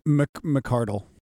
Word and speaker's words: McArdle: And tonight we have a great episode of Angela McCradle. Is that how I think I say her name? – McCardle.